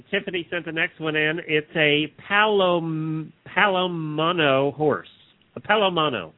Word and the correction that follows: Palomino: Palomino. You're right. Palomino. I'm sorry. I mispronounced that name of that horse.